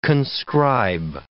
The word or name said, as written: conscribe